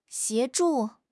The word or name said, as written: 协助